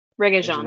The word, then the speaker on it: Regé-Jean: Regé-Jean. – Regé-Jean? Okay. – Regé-Jean? – I was told it's like reggaeton, but Jean.